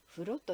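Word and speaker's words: 風炉: 風という字にいろりの炉と書いて風呂と読みます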